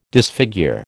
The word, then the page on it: disfigure: American English